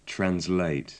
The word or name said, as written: translate